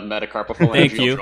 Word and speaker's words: metacarpophalangeal: Right, so first off, excellent pronunciation of metacarpophalangeal joint.